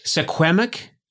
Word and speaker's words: Suquamish: So you are a member of the, I want to make sure I say it right. Suquamish? – That's pretty good. No, that you did really well there.